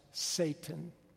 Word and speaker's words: Satan: And so the S-T-N is pronounced Satan.